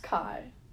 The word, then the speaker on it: Chi: It's pronounced Kai. – Oh my god, wait, I learned that in AP Bio, and guess what? Fuck AP Bio, so Chi.